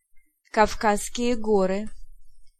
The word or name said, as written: Caucasus